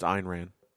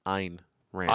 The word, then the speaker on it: Ayn Rand: Aaron Rand? – Drill hands? – No. They did you say Aaron Rand? – Yes. – It's Aryan Nation. – Did I say it wrong? – It's Ayn Rand.